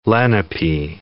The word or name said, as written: Lenape